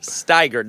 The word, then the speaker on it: Steiger: Pierce, and you guys work for a ministry called Steiger. Did I say it right? – You yes. said it right.